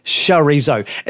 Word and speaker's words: chorizo: It is pronounced chorizo. And once again, apparently, Mary Berry says chorizo incorrectly.